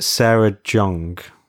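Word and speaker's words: Sarah Jeong: I believe you pronounce it Sarah Jung. Some people pronounce it with like a Jung.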